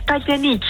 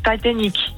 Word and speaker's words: Titanic: Nou, het is Titanic. Het is zeg maar hoe Celine het op zijn Engels met haar Franse accent zou zeggen. Dus Titanic.